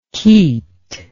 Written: keet

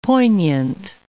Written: poignant

